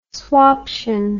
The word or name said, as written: swaption